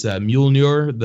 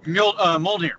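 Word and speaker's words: Mjölnir: it's Mjolnir, the fucking hammer that Thor wields. – Mjolnir, yeah.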